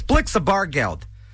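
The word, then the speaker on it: Blixa Bargeld: This is, I gotta look at the name pronunciation because it's Blitzabargeld from Einstein's in die Neubauten, and I can say that correctly, which means collapsing new buildings.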